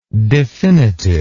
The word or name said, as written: definitiveness